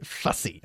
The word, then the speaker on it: fussy: Fussy. – Muy bien, pero muy mal pronunciado. Y por eso te lo pregunté, porque se dice fussy, ¿ok? Fussy. Una vez más, bajando la mandíbula.